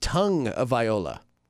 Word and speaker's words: Tagovailoa: The last name is actually supposed to be pronounced Tagovailoa. – Oh, Tagovailoa? – Interestingly enough, because there is no N in there.